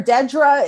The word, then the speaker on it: Dedra: Um, Deidre Deidre? – It's a Deidre or Dedra. It's D E D R A is how you spell it.